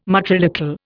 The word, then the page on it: matrilocal: Indian